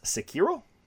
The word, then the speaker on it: Sekiro: Okay, so number five is, and I believe it's properly pronounced Sekiro.